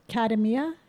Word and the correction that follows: Katmai: Katama. – No, Katmai. – Okay, Katmai.